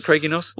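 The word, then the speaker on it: Craig-y-Nos: Pronunciation, is that right, Craigie Nos? – Well, I don't speak Welsh, but on the Brecon part, it's Craigie Nos. – Craigie Nos. – It's more like Craig in Germany.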